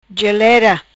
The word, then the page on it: Gelera: patua